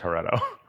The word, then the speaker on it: Heretto: And then after a couple of days, I was like, I can just add a T to this and we can pronounce it the way that Casey wants to pronounce it, which is Heretto.